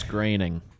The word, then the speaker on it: Groening: I believe it's Groening. – Graining? – I don't know. How is it pronounced, Bob? Groaning or graining? – It's pronounced gravy.